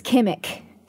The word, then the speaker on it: Kimmich: My last name is Kimmick. So like a CK, but it ends with CH.